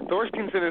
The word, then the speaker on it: Thorsteinson: I mean, Thorsteinson isn't that bad, but if you look at Dave's name, the way it's spelt, W-A-S-Y-L-I-W, it really doesn't make any sense at all on how to say it.